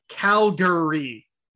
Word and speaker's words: Calgary: Calgary. Say it right. – I know it's Calgary. I just like to say Calgary.